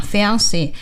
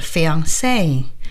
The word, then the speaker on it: fiancé: 说的是在英语里边很多法语词就原模原样的端来所以呢就像这样 Fiancé 也就这样端来了而且法语的发音重音往往在最后一个要是真正的法语呢是 fiancé 会说这样的但是到英语里边就 fiancé 就变得